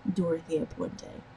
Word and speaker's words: Dorothea Puente: Some people say her name different, but I say her name Dorothea Puente.